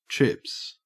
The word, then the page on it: trips: Australian English